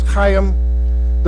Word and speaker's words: Chaim: The Hebrew pronunciation is Chaim, the Ben Chaim edition, which is the second edition of the Rabbinic Bible, okay?